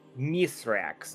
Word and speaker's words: Mithrax: All right, so first off, we have Mithrax. That's his proper pronunciation in the Elixni language.